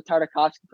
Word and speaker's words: Tartakovsky: Tartakovsky. – There we go. Okay. I haven't seen all of the Tartakovsky Clone Wars.